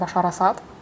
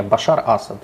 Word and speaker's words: Башар Асад: Значит, 22 а, сентября в Пекин приехал президент Сирии Башар Асад. Правильное ударение ставлю? – Да. – Потому что я слышала вариант Башар Асад. – Не, Башар Асад. – Башар Асад, окей.